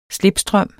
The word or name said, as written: slipstrøm